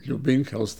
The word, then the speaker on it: Ljubinko: and I, I, I, wouldn't even try to explain my my first name because L Ljubinko is spelled L J U B I N K O, hmm.